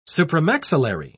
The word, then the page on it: supramaxillary: British English